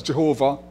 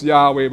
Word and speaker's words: Yahweh: Earlier, the scholars thought they didn't know how to pronounce it because they never pronounced the formal name of the Lord. And so it is written in the Hebrew with just the consonants, and so they didn't know how to pronounce it, so they thought it might have been pronounced as Jehovah. And then later on, the figure said, no, it's Yahweh, but it's the same God they're talking about who revealed himself to Moses and others in the Old Testament.